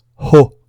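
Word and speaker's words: ĥo: Le H se prononce en expirant un peu d'air, comme dans l'anglais hat, chapeau. C'est donc la lettre ho.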